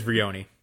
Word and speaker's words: Vrioni: I imagine that when uh, now, first off, I believe it's Veroni, right? Uh, I got. – You're right. I had my. You're right. My, it is. It is Vrioni.